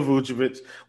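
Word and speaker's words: Vučević: The guy whose name rhymes with my handle. – Yes. Vooch. Nikola Vucevic.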